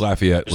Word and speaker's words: Lafayette: LaTerrence Welch, a cornerback from Lafayette, Louisiana. – It's Lafayette, Louisiana. – There's my J school coming out where they Louisiana they Louisiana it. Does it right, and people up here pronounce it wrong.